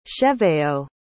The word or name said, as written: cheveyo